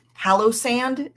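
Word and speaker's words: Palossand: I don't know, maybe this is cheating because I picked Pallosand is how you say it, which again, this is pure aesthetic.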